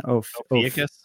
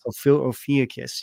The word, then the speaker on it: Ophiuchus: What do we say again? – Ophiuchus. – Ophiuchus, yes. So I feel like I keep wanting to say Oedipus.